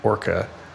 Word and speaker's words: Orca: I think he pronounces it Orca, although he does this design thing that I hate, which is that the A in Orca is actually a lambda, and so mm-hmm.